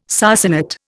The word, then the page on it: sarsenet: Indian